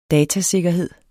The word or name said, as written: datasikkerhed